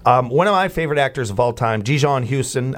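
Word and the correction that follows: Djimon Hounsou: Ji-Mon Hasu.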